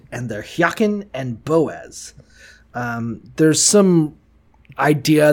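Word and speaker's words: Jachin: Hyakin, pronounced Hyakin, stands for he will establish, and Boaz means in God is its strength.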